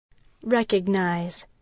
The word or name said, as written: recognize